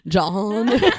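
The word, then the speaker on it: Jann: I said like all of that wrong. It's actually John.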